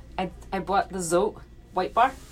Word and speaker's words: Zote: Z O T E.